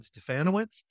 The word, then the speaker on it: Stefanowicz: So, my name is Jed Stefanowicz, rhymes with Bananowicz for all of you out there who need to know how to pronounce that long name.